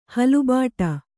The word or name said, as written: ಹಲುಬಾಟ